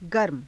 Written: garma

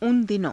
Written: una dino~

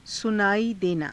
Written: sunaaii denaa

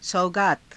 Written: saugaata

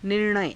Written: nir_naya

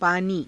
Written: paanii

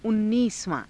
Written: unniisavaa~

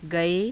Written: gae

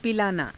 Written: pilaanaa

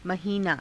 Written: mahiinaa

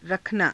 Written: rakhanaa

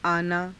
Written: aanaa

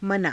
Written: manaa

